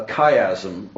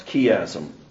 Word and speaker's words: chiasm: This is called a chiasm, or sometimes pronounced chiasm.